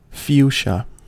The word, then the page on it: fuchsia: American English